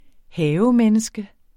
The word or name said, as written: havemenneske